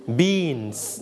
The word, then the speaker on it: beans: นะครับ milk and then what beans นะเราจำว่า beans ไม่ใช่บินนะลูก beans ออกเสียงยาวนิดนึง